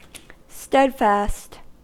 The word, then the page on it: steadfast: American English